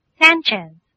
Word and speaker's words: Sanchez: Sanchez. – Could you spell that, please? – S.A-N-C-H-E-Z.